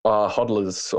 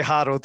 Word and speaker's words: HODL: Hey, how do you pronounce the HODL when people say that? – Hodlers or hodl. Yeah. I think the story was that someone, you know, misspelled hold or something. So hodl.